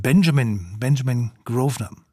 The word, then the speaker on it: Benjamin Grosvenor: Und dazu haben Begleiter Nicola Benedetti, Geige, Chico Kenny-Mason, Cello und den Pianisten habe ich vorhin falsch ausgesprochen. Er ist natürlich Engländer und heißt deswegen mit Vornamen Benjamin Grosvenor.